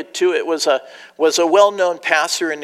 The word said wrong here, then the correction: Truitt: I said his name wrong. It's Truitt. And he was invited to dinner at the ranch of a very wealthy Texas businessman.